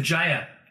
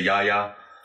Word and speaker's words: Jaya: Yeah, I think a lot of the Americans say Jaya. I always said Jaya. A lot of the Dutch and Germans say Jaya. – Jaya da Cat. – Yeah, because a J in Dutch is a Y.